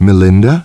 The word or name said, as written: Melinda